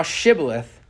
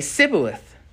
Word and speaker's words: Shibboleth: And if he said, Nay, then they said unto him, Say now Shibboleth. And he would say, Sibboleth, for he could not frame to pronounce it right.